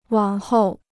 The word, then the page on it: 往后: wǎng hòu